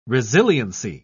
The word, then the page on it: resiliency: British English